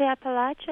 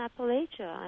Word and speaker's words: Appalachia: They say Appalachia and Appalachia, and I don't really know what is more correct, but I've heard it said both ways for so long that I imagine it's to use both.